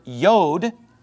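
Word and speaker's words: Yod (יוד): Which is pronounced in modern Hebrew, Yod, okay? And it's spelled J-O-D, right?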